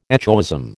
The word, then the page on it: echoism: American English